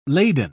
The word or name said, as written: laden